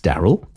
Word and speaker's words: D4RYL: An actual magic robot, spelt D4RYL for some reason, but pronounced Daryl.